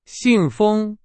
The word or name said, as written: 信封